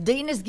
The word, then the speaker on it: Dinas: My name is Dinas Gilmore. I need to tell... – You said it right, Dinas. – Oh, I'm sorry. – No, you said it phonetically right. It's supposed to rhyme with penis.